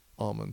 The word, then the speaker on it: almond: So some of them say, well, they beat the L out of it and call it almonds.